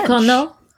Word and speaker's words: Ducournau: I want to say it like a French person. – Like a French person? Yes. Yeah. – She's French. – Ducournau.